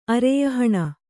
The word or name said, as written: ಅರೆಯಹಣ